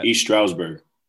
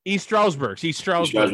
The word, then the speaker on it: East Stroudsburg: East Stroudsburg. – East Stroudsburg University.